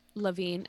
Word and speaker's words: Levine: Is that how you say his name? Levine. Okay. I couldn't remember.